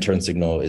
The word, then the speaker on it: Turn Signl: And Turn Signl is spelled T U R N S I G N L. So it's spelled turn signal without an A uh, that's because there's a lack of access to justice.